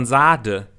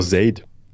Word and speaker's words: Sade: Ja, oder von, von Sade, der Sängerin. Wo die heißt, glaube ich, Sade, ne? Wird die, glaube ich, ausgesprochen. Äh, was ja auch. Die heißt doch Zaid.